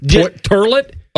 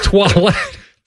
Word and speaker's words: Toilette: Yeah, what did you pronounce it? Turlet? – Eau de Toilette? – It's Toilette.